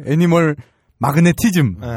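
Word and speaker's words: Animal Magnetism: 스콜피언스의 애니멀 매그네티즘. – 그냥 제식으로 발음해드려요. 애니멀 마그네티즘.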